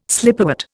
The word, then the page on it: slipperwort: Indian